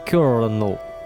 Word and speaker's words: colonel: В XIX веке ревнители английского языка решили вернуть его истоком, заменив на письме букву R на L. На слух же хрен редьки оказался не слаще, и произношение полковника осталось прежним. Ну, а американцы, известные любители везде слышать звук «r», в своей речи его до сих пор обозначают «colonel». R.